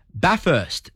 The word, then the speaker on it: Bathurst: I've always found it's pronounced Bathurst, not even a T on the end.